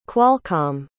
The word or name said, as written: qualcomm